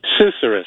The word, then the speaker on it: susurrus: Am I saying that right? – Susurrus.